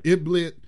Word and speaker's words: Idlib: Idlib.